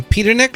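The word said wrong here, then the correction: Peterik: Peterick, I'm sorry, from Ides of March from Survivor.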